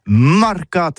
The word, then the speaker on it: marcats: Eh bien, d'ici à toutis, mais le mercat de l'Uni ou le mercat des rebelles, mais en gascogne toulousano, c'est prononcé sur les marcats, avec un A marquette.